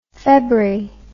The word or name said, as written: febri